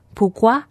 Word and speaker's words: Pourquoi: 法文的为什么就是 Pourquoi。金宁的发音不是很标准，但是中文听起来就很像蒲瓜，听起来很可爱。